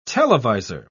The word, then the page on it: televisor: British English